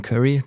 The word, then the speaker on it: Curry: Es gibt kein Wort für positiven, passiven Cashflow, deswegen spreche ich da immer von Curry. Das kommt aus dem Englischen und spricht sich quasi wie das Gewürz, aber hat eine andere Bedeutung in zumindest meiner Welt.